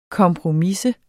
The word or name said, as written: kompromisse